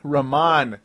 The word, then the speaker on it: Ramon: Ramon Sessions, as I'd been saying, Raymond Sessions forever over the last couple of years when he was a member of the Milwaukee Bucks. And then we went with Ramon Sessions.